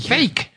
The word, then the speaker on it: Feige: Además de eso, Kevin Feige, no, ¿cómo se pronuncia Feige? – Fake. – Es fake.